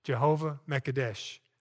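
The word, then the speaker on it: Jehovah M'Kaddesh: That's M apostrophe K-A-D-D-E-S-H. M apostrophe K-A-D-D-E-S-H. Jehovah Makedesh or Makedesh.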